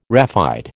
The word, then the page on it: raphide: American English